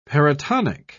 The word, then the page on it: paratonic: British English